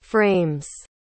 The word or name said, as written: frames